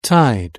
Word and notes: tide: British English

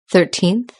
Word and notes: thirteenth: British English

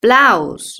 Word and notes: blouse: British English

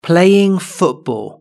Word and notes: playing football: British English

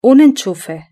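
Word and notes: un enchufe: Spanish (Spain)

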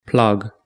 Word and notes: plug: British English

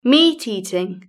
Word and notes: meat eating: British English

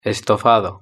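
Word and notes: estofado: Spanish (Spain)

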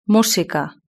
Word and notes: musica: Spain Spanish

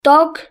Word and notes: dog: British English

